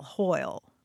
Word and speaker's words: hwyl: This story takes place in Wales, and the word they're using is spelled H-W-Y-L, hoyle. – Nice. W is a vowel. – W as a vowel, as you see in Welsh.